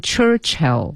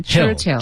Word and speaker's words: Churchill: 应该读成 Churchill. – Yeah. – 一个是 Church, 一个是 Ill, 读成 Churchill.